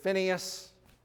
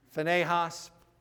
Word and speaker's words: Phinehas: That word, Phinehas, you may have heard it, Phineas, Phinehas. I've heard it pronounced both ways.